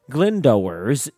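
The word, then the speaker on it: Glyndŵr: Glyndwr's Way, pronounced Glyndwr's in Wales, slightly longer trail at 135 miles.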